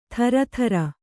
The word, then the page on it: ಥರಥರ: Kannada